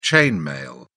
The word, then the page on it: chain mail: British English